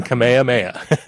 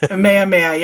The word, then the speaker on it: Kamehameha: Can we ask for your kokua, I'm assuming that's how that's pronounced, which is the Hawaiian word for help, and promoting K6K, the Kik Kamuamua, yeah, celebration. – King Kamehameha. – Kamehameha, yeah, sorry.